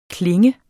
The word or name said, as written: klang